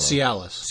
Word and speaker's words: Cialis: I mean, and this is stuff that will come to me, and it has a certain message, and it's Callus pills, or how do you say that? Calius? Callus? – I don't know. Cialis.